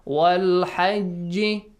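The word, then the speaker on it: Wal hajj (والحج): Wal hajj. Look, I made the qalqala sound.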